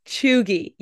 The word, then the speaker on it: cheugy: Cheugy, yeah.